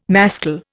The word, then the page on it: mascle: Indian